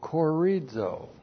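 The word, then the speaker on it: corizo (χωρίζω): C-H, long O, R-I-Z, long O, corizo.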